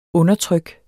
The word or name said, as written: undertryk